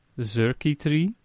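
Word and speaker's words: Xurkitree: Ik zei Xerky Tree. – Oké. Okay. Nou, je moet het... De X moet je dus inderdaad gewoon uitspreken als Z. Dus dan wordt het... Xerky Tree.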